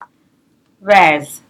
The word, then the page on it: bez: Teotitlán del Valle Zapotec